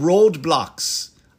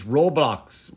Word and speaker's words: Roblox: Which is not called Roadblox. I thought it was Roadblox. It's Roblox or Roblox. I'm saying it wrong.